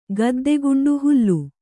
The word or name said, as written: ಗದ್ದೆಗುಂಡು ಹುಲ್ಲು